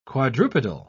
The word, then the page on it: quadrupedal: British English